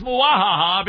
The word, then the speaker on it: muahaha: See, it's with the accent on the third syllable there. Today's word is muahaha because Tony is actually going to be filling the content of Wretched Radio.